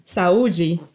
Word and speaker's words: Saúde: Saúde. – Oh my God, that's so beautiful. – You have to try it. You have to try it. Saúde. – Saúde. – Perfect. That's perfect.